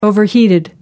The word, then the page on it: overheated: American English